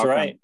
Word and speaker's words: Bauckham: we've got Eric Dressen, Bryce Knights, Christian Hosoi, Chris Bauckham. I hope I'm saying his name right. – Yeah, Chris. – That's Bauckham. – Right.